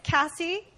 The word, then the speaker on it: Kasi: Cassie, it's spelled K-A-S-I.